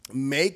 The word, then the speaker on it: Mae: We've also got, I think it's pronounced, it's May, M-A-E, right? That's how you say that? May? Because when I think of May, I think of M-A-Y, but... – I would say it's May.